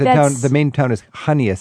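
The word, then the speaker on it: Chania: I think, is how you pronounce it, Kania? Is that where I should be looking at Hania? You mean Hania? – Yeah. – Hania.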